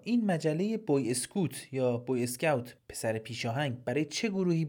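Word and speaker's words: Boy Scout: مجله بوی اسکوت با تلفظ فرانسوی یا بوی اسکاوت با تلفظ انگلیسی این اتفاق در ماه آپریل 1922 افتاد.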